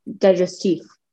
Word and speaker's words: digestif: How do you say it? You said it fancier than I did. – Digestif. – Yeah, I guess that's what I said.